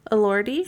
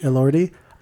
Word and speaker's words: Elordi: Elordi. – Elordi.